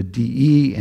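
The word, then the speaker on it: de (δέ): Then you get the Greek word in the Greek and the English, how it is the D-E and how it's pronounced de, and then the definition, that's key.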